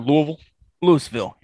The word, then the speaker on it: Louisville: Okay, Louisville. Did I say it right? – Louisville, yeah.